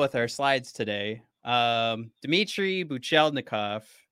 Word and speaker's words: Buchelnikov: Are we waiting on Buchelnikov? Buchelnikov? I think I've skipped a syllable.